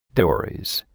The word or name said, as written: Dories